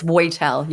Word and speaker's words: Woitel: I actually watched a video to see how you say it because I didn't know. So it's Woitel.